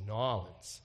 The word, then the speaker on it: New Orleans: That's not how they say it. It's Nawlins.